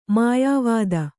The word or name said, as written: ಮೆಯ್ಯವುತೆ